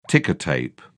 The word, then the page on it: ticker tape: British English